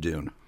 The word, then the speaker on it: Doon: So I wrote the tune Down the Dune. – But spelled it D-O-O-N.